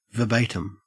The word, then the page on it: verbatim: Australian English